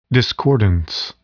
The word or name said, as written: discordance